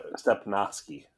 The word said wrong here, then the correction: Stepnoski: Noski. So you were there. You just didn't say it right.